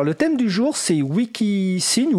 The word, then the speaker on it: Wikisign: Alors, je vais prononcer Wikisign parce que sa s'orthographie en anglais, W-I-K-I-S-I-G-N.